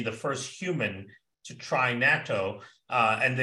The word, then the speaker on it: natto: Oh, natto, natto, yes. – How do you spell it? – So N-A-T-T-O. – Okay. – Natto. – Okay. – Japanese would say natto. To properly pronounce it.